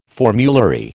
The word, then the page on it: formulary: American English